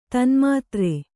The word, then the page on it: ತನ್ಮಾತ್ರೆ: tanmātre